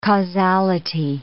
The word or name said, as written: causality